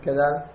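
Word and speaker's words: gadal (גדל): It could be said, the first word, it's gadal. If you don't say it correctly, you're saying it with a kuf, it's kadal. It's not it's kadal, it's gadal with a gimel.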